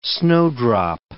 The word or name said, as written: snowdrop